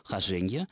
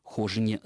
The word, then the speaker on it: хожение: то там это слово зарегистрировано только с ударением на втором слоге «хоженье», и, следовательно, нет никаких оснований называть эту книгу хожине за три моря.